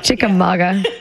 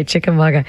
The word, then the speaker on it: Chickamauga: Did I say that right? – Chickamauga. – Yeah. – Yeah, it's a Cherokee word. – Okay, Chickamauga.